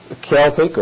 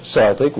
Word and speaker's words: Celtic: But probably most, being of Celtic or Celtic, which pronunciation you prefer,